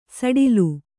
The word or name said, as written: ಸಡಿಲ